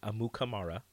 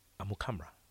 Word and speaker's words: Amukamara: It's not Amukamara. Huh. It's Amukamara. – Amukamara. And we've been saying it wrong. Forever. – No, he said that's how his parents say it, but when they came to America, it's Amukamara. Amukamara. Amukamara.